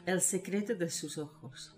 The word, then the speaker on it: El secreto de sus ojos: And this week we're discussing Juan José Campanella's The Secret in Their Eyes, or will helpfully pronounce it in Spanish. – El secreto de sus ojos.